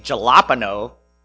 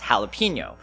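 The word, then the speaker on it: jalapeño: Versus, in order to make the H sound in Spanish, you have to spell it with a J. So, of course, when you say jalapeno, it doesn't make any sense. It's jalapeno, right?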